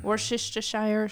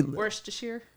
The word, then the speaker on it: Worcestershire: Worcestershire? – Yeah, Worcestershire, something like that. Yeah. But it's spelled Worcestershire. I mean, just get... The British need to go away. That's ridiculous. Worcestershire, but pronounce it Worcestershire.